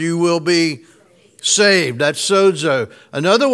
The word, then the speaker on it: sozo: literally the Greek word is the word sozo. S-O-Z-O is the English, I guess, transliteration of that. What's the English? Anyway, sozo is how you pronounce the Greek word literally.